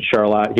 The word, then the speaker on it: Charlotte: Charlotte, Michigan, where I live, spelled like Charlotte. We pronounce it Charlotte here in Michigan.